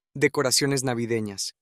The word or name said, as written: decoraciones navidenas